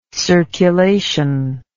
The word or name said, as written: circulable